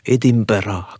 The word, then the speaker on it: Edinburgh: On traverse la mer du Nord, cap sur les rives de l'Écosse, direction Édimbourg, ou Edinburgh, comme on doit le prononcer en Écosse.